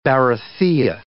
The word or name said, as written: barathea